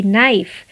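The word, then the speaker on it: knife: नाइफ again के silent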